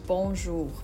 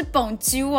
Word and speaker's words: Bonjour: 对，因为大家听到法文，第一个想到就是 Bonjour 嘛。那大家的发音，对，就是嗯，还需要加强一下。 – 对，要加强，不是蹦啾哦，啾屁啾，我真的会想要从你的脸上打下去。